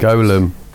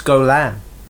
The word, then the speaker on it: Golem: Golem. – No, it's Golan. – Oh, it says Golem on the program. – I know, it's spelt Golem, but it's Golan. That's how they fucking pronounced it in the film.